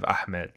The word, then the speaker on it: Ahmed: Mitch, I just want to flag really quick wonderful pronunciation of Ahmed.